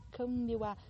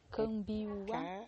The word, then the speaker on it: Kambiwá: Kambiwá. – C-A-M-B-I-U-A. Kambiwá. – K-A-M-B-I-W-A. – M-B-I-W-A.